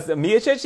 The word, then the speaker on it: Miocic: Well, Miocic, yeah.